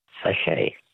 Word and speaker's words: sachet: Words that end with a silent T. Which word for a small sealed pouch literally means little sack in French? – Sachet. – A sachet. S-A-C-H-E-T.